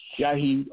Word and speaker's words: Shaheed: My name is Shahid Omar, and it's spelled S-H-A-H-E-E-D. Last name is O-M-A-R.